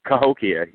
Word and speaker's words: Cahokia: I don't know a ton about, I don't even remember how to pronounce it. What is it called? Cahokia? – Cahokia. Yeah.